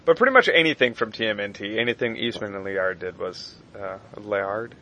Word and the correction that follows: Laird: Yeah, I think that's Laird. – How you pronounce it.